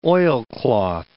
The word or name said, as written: oilcloth